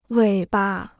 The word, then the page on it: 尾巴: wěi ba